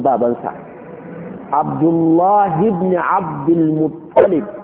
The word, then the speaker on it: Muttalib: ko Muttalib ake cewa ba Muttalabu ba ba Muttalabu ba a Muttalib